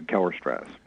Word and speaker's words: Kellerstrass: Kellerstrass, is that how you pronounce it? – Yeah, Kellerstrass. Right, Kellerstrass. – S-T-R-A-S-S. Okay.